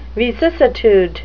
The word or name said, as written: vicissitude